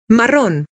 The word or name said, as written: marrón